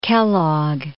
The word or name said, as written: Kellogg